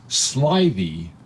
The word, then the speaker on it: slithy: And it says, pronounce slithy as if it were two words, sly thee.